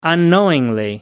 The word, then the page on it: unknowingly: British English